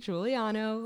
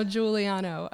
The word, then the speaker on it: Guiliano: My name is Katie Guiliano. Like Becky said, I'm married into a Italian family with a four-syllable last name.